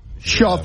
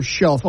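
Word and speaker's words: Shough: Shuff. Yeah. I hear shelf all the time. – Yeah. Shuff? – Like rough, no, like with SH, yeah.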